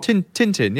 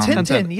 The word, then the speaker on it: Tintin: You know, like, I don't know how you say it in English, Tintin, like the Belgium guy with his little dog. – Tintin, yeah. – Tintin. – Ah, Tintin, yeah.